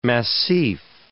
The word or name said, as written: massif